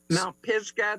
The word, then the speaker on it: Mount Pisgah: But because it looks like there's so many great stops along the way, like the, how do you say it's Mount Pisgah? – Mount Pisgah, that's up near Cripple Creek.